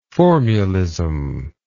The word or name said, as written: formulistic